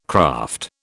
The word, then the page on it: craft: British English